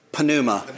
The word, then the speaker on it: pneuma: And pneuma, how is that really supposed to be pronounced as a word by itself? Pneuma, right? Pneuma, right.